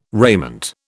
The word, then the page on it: raiment: British English